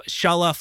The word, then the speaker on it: Csala: I like how he explained it through anime terms, where he said, so C-S-A-L-A is pronounced the same as the famous Dragon Ball opening theme, Shala, as in head Shala. And then he said his last name, F-O-O-R-I-A-N, with a lot of, whatchamacallits? – Umlauts and stuff, yeah.